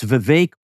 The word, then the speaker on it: Vivek: It's Vivek, rhymes with cake, by the way.